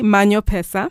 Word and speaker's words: Manyo Pesa: Now, there's your new song featuring Watendawili, Manyo Pesa. Mm-hmm. Did I say that right? – Yeah, right.